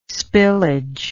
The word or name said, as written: spillage